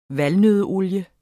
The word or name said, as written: valnøddeolie